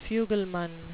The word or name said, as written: fugleman